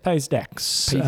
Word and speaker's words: Pays d'Aix: How do you say that? – Pays d'Aix.